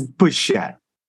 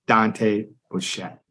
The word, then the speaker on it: Bichette: Okay. It's Bouchette. I don't know if I can make it so everybody can hear it, but it's Dante Bouchette.